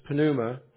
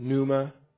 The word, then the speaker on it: pneuma: And I say pneuma, and I know in English we pronounce it pneuma. In Greek, there are no silent consonants that I know of, so I'm assuming that the word is actually pronounced pneuma in Greek. But we would say pneuma in English. We wouldn't pronounce the P.